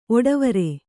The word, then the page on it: ಒಡವಾರ: oḍavāra